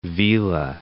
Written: vela

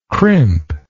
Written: crimp

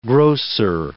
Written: grocer

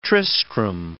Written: Tristram

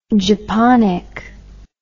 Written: japonic

